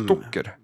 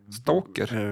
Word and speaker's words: Stoker: Uttalar jag Bram, Bram Stoker? – Stalker? Eh, Bram Stoker. ja. Stalker. Var han en Stoker? – Med O, stalker. Stoker. Stoker.